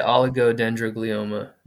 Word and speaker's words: oligodendroglioma: So you were diagnosed, I'm probably not going to be able to pronounce this, I'm really sorry, but you were diagnosed with an dendroglioma tumor, is that correct? – Yeah, oligodendroglioma, yeah. – Yours sounded better.